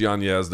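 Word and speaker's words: Yáñez: Yes, it is pronounced Yanez. There is an Enye over the N. He doesn't pronounce it that way. You can say Yanez if you want.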